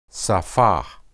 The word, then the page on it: saphah: hebrew